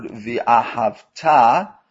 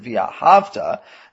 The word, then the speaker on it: V'ahavta: By the way, the note, the word V'ahavta must be pronounced with a stress on the final syllable, because that makes it a future tense word, meaning you will love as a commandment. If you pronounce it V'ahavta, as I mistakenly did at the beginning, it becomes incorrectly a past tense verb, and you loved God, which is not the meaning of this section.